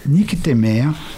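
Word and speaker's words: nycthémère: N-Y-C-H-T-E-M-E-R-E. Nick Temer.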